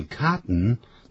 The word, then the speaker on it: cotton: 我们就发音可以看得出来，它这个 cotton 在美式英语里头啊，每一个字的结尾是 den 或者是 ten 的这样音的时候，用鼻音出来的。